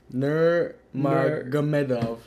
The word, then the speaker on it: Nurmagomedov: I had a Russian friend tell me how to pronounce it. It's Nurmagomedov. – Nurmagomedov.